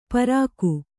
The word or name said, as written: ಪ್ರಾಕು